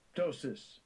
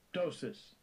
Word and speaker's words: ptosis: Ptosis. – Ptosis.